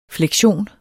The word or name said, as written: fleksion